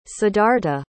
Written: siddhartha

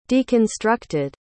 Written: deconstructed

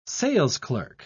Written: salesclerk

